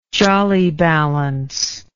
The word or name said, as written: jolly balance